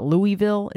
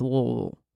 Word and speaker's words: Louisville: You pronounce it as if you're having a stroke. It's not Louisville, it's not Louisville, it's Louisville. Just pretend you're stroking out when you say it.